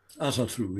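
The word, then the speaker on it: Ásatru: Is it Asatru? – Asatru, yes.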